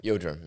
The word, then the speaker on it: Yildirim: I can't pronounce his first name that well, but it's just Yildirim. – Yildirim.